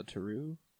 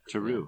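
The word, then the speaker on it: Terreux: Is it the Brewery Terrell? Is that how you spell it? – Well, the brewery is the brewery, but they have like a little, I guess it's like a sect of their brewery called the Taru, and I believe that's all sours. – Yeah. – Taru, yeah. – Huh? So I just butchered that.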